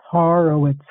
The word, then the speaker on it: Horowitz: H-O-R-O-W-I-T-Z spells Horowitz, Horowitz.